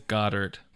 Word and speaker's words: Goedert: I looked it up. It is Goddard.